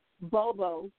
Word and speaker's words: Bobo: Bobo. B-O-B-O.